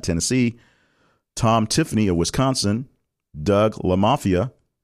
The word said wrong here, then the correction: LaMalfa: LaMalfa, I'm sorry,